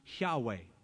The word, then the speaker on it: Yahweh: Some think it's Jehovah. But most scholars feel that that is sort of a corruption of the term, that it should be pronounced Yahweh.